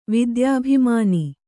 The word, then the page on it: ವಿದ್ಯಾಭಿಮಾನಿ: kannada